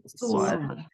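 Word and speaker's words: SOAR: det var faktiskt det här SWOT kontra nu vet inte jag vad det, hur man uttalar det. Sår. – Sår.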